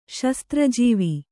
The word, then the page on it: ಶಸ್ತ್ರಾಜೀವ: Kannada